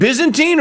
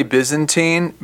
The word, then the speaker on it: Byzantine: Is it Byzantine or Byzantine? Or are both of us wrong? – I say Byzantine, but I do believe that either pronunciation is accepted.